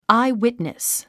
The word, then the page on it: eyewitness: áiwìtnis; アイウィットニス